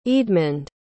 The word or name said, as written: eadmund